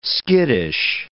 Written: skittish

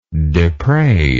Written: depravingly

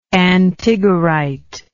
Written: antigorite

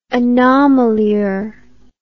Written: anomalure

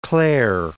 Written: Clare

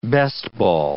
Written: best-ball

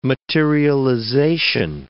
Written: materialization

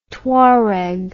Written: Touareg

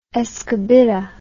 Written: escobita

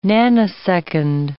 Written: nanosecond

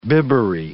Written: bibbery

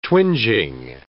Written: twinging

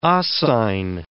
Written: oscine

